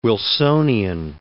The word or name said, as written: Wilsonian